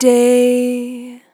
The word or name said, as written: Long Day